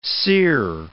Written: sere

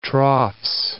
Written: troughs

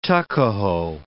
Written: tuckahoe

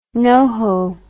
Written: noho

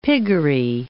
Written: piggery

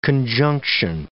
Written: conjunction

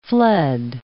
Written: fled

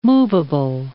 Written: movable

